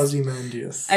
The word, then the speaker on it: Ozymandias: Ozymandias? – Yes. Yeah, Ozymandias.